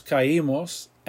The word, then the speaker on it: caímos: We heard, oímos, O-I-M-O-S, accent. We fell, nos caímos, accent on the I.